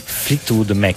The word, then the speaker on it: Fleetwood Mac: No, lì c'era, perché stavo parlando dei Flatwork, stavo pensando ai Flatwork Mac, però lì Mick Flat, c'è ancora un Flatwork, no? – Fleetwood Mac. – Fleetwood. Non riesco a pronunciarlo.